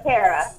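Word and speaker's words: Terra: Not Tara, but Tara.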